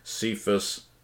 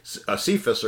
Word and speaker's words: Cephas: James, Cephas," or Cephas, "and John."